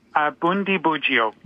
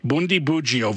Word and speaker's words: Bundibugyo: Bundibugyo. – Bundibugyo virus.